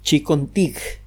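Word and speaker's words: Chincoteague: Hay una isla al eh, noreste de los Estados Unidos que se llama la isla de Chicontig. Se escribe Chiconteague, G-U-E.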